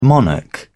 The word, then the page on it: monarch: British English